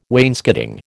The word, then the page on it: wainscoting: American English